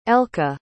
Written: elke

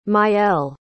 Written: maëlle

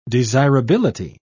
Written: desirability

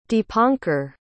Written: deepankar

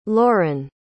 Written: lorin

